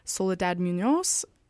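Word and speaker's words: Soledad Muñoz: A local label that is all female electronic artists run by Soledad Munoz. Is that how you say it? – Yeah.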